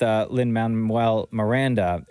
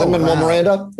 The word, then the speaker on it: Lin-Manuel Miranda: The trick is in Spanish. With the Spanish pronunciation, it gets very easy. Lin-Manuel Miranda. But Lin-Manuel Miranda is tougher. All those diphthongs. We don't have diphthongs in Spanish.